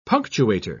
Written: punctuator

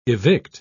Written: evict

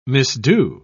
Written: misdo

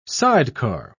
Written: sidecar

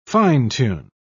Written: fine-tune

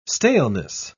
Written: staleness

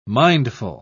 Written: mindful

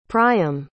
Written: priam

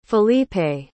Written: felipe

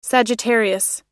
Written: sagittarius

